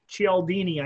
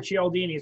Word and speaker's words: Cialdini: Okay, there's a book out by Robert Cialdini, I think is how it's pronounced. It's C-H or C-I-A, but Robert Cialdini, it's called the Art of Persuasion or the Art of Persuasion.